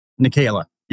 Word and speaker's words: Nekhaila: So when Stephen Nikayla, and he's kind of a new guy, so I'm pretty sure I'm pronouncing his name properly, Nikayla? – Nikayla, yes. – Yep. N-E-K-H-A-I-L-A.